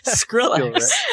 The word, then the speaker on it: Skrillex: What did you say? Skillrix? – What is it, Skrillex.